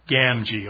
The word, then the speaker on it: Gamgee: And my favorite quotation from him is from Sam Gamgee. Is that a pretty good pronunciation? How do you say it? Gamgee.